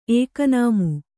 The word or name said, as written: ಏಕನಾಮು